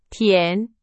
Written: 填

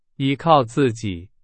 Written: 依靠

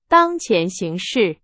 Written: 形势

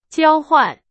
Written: 交换

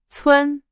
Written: 村

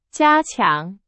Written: 加强

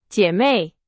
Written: 姐妹

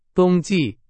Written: 冬季

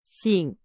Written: 醒

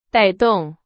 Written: 带动

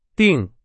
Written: 订

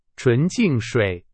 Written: 纯净水

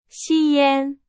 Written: 吸烟

